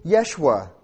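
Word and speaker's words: Yeshua (ישוע): Often pronounced in the Hebrew, Yeshua. Sounds a lot like Joshua, doesn't it?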